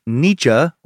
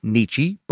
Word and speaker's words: Nietzsche: They would go to prison after Nathan Leopold became convinced that through a misreading of philosopher Friedrich Nietzsche, or Nietzsche, both pronunciations are acceptable, he and Loeb were Nietzsche's supermen.